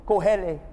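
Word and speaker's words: Kohelet: Kohele. Tell your neighbor Kohele. – Dígale a su vecino. – Say it like that.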